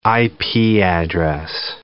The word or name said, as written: IP address